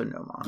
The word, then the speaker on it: Gnomon: and it's saying, like, O as in code, and O as in body for the second one. So Gnomon. – Yeah. Gnomon. Okay. Leap, Gnomon, Jubilee, and Annie.